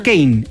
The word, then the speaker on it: Keyne: Se escribe K-E-Y-N-E. Pero en la casa le dicen Kane, el hermano de Lamine Yamal.